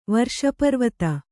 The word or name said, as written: ವರ್ಷಪರ್ವತ